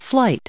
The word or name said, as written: flight